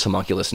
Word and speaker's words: homunculus: This quartet has produced two releases of original music, 2012's Pole Factors and last year's Homunculus, a word that escapes the lexicon of most English speakers. Would you say so, David? – I'd agree. I concur. – What in God's name does homunculus mean, guys?